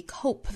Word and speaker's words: cope: 好我们再看美音 How do you guys cope with the ever 这里 cope 尾部的 p 的发音也是不十足爆破的因为后面遇到辅音嘛这个时候是 cope with 而不是 cope with